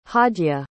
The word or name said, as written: hadya